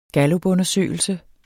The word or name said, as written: gallupundersøgelse